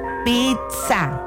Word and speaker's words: pizza: Es que la manera correcta es con doble, con Z. Ajá. – A lo mejor para pronunciarla fuera de México es pizza, ¿no? Y aquí es con T, pizza. Al momento de pronunciarla. – ¿Es correcto entonces pizza? – Sería con T. Al momento de pronunciarla va con T.